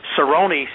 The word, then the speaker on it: Cerrone: Obviously, his flexibility, you know, in the Cerrone, see, I got the name right this time, fight. – Good work, Cerrone.